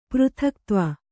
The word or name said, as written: ಪೃಥಕ್ತ್ವ